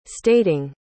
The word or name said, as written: stating